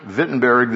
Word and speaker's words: Wittenberg: So you call it Wittenberg, Wittenberg, the German pronunciation, or Lutherstadt, Luther City, if you want.